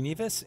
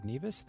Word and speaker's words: Nevius: And you can find me on Twitter at G Nevis and look me up on Facebook at Greg Nevis. That's spelled N-E-V-I-U-S.